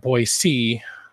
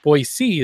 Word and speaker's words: Boise: But of course, if you call it Boise, a lot of people will get mad at you. – Yeah, or sure. Is it Boise? Boise is the right way to say it. Boise. – Oh, Boise. I see. – Yeah. So yeah, if you put like a Z in there, people will like get their pitchforks and everything.